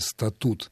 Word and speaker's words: статут: Относительно пары слов «статус» и «статут», я прежде всего замечу, что второй из них следует произносить с ударением на втором слоге «статут».